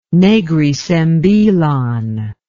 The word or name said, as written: Negri Sembilan